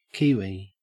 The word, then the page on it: kiwi: Australian English